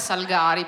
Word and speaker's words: Salgari: Salgari, non Salgari. Non Salgari. La pronuncia corretta è Salgari, perché era veneto...